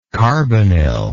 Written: carbanil

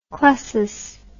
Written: clasis

